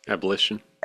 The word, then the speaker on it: abolition: It helped bring about the abolition. – Abolition. – Abolition, thank you. I was putting the wrong syllable on the, but you can be, we're lazy, and so we're not shrewd.